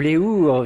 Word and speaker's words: ours: Où les ours vivaient. – Non, les ours. – Les ours. – Il faut faire la prononciation, mais on ne prononce pas le S final au pluriel du masculin d'ours.